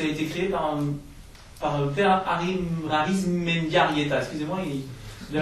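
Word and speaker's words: Arizmendiarrieta: Arismendiarrieta. – Le basque, ça se prononce comme ça, c'est écrit.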